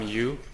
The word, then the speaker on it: Yu: My name is Tom Yu.